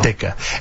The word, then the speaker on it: Ditka: And the world would not be the same without Ditka. And again, the T is silent.